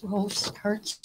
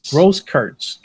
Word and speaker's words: Grosskreutz: And then the fifth charge was that guy, Gage Grosskreutz. Is that how you pronounce it? Gage Grosskreutz. – Grosskreutz. Gage Grosskreutz.